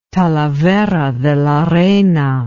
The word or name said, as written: talavera de la reina